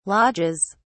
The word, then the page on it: lodges: British English